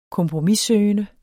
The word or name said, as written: kompromissøgende